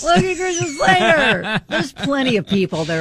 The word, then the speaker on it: Christian Slater: Christian Slater? – Yes, Christian Slater.